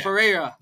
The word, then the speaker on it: Pereira: They finally announced this grudge match between the two fighters, Alex Pereira, whatever you want to pronounce it. Speak Spanish. You know how it is. – Alex Pereira. – Thank you very much.